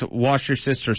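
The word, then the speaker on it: Worcestershire: Haley messaged in and said it's Worcester sister sauce. – That's that helps with the pronunciation.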